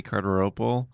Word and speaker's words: Cardarople: I'm on Twitter, Matty Cardarople. My last name is spelled Cardarople. I'll say it again if you're writing it down. Cardarople.